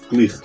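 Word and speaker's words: Klich: And what do you say his name? Matthias Klick. – Say it how you want. But he did Klick. Isn't it Klick? It's Klick.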